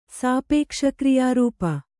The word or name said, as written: ಸಾಪೇಕ್ಷಕ್ರಿಯಾರೂಪ